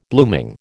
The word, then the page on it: blooming: American English